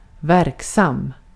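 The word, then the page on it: verksam: Swedish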